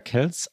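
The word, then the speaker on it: Kelce: Spricht man ihn Kelsey oder Kels aus? Er schreibt sich nämlich K-E-L-C-E, -E, aber Kels sagt er selbst.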